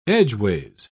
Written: edgeways

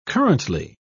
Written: currently

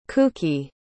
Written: kouki

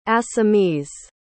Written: assamese

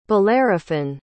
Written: bellerophon

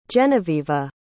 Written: genevieva

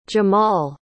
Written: jahmal

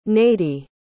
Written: nadie

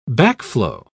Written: backflow

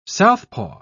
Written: southpaw

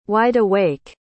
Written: wideawake

